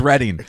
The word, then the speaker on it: Reading: Yeah, R E D D I N G is Reading.